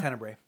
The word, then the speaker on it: Tenebrae: Is it Tenebra? – Tenebrae. – Tenebrae? – Yeah. – Okay. Tenebrae, which will come in a special 40th anniversary. – It's not from Jersey. You Tenebrae. – You Tenebrae.